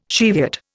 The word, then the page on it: Cheviot: Indian